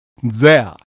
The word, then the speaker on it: there: 出だしを there と発音しないようにしてください。 there。つまり th の音は舌先を噛みながら発音します。